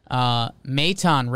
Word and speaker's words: Maton: Pronounce the A. Maton representing 217.